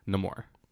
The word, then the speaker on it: Namor: I saw a clip where he's talking to the queen, and he says, Namor. – Yeah. – So I guess that's how you pronounce it.